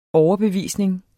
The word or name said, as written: overbevisning